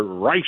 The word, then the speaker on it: Reich: And I think you profiled Robert Reich very well, but you mispronounced his name. – Okay, it's Robert. – I understand to be Robert Reich.